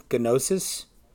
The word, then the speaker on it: gnosis: Is it really spelled gnosis? – Yeah, okay. G-N. And you pronounce both. Or like, we would say pneumatology, like P-N-E-U, but in Greek you would say pneumatology.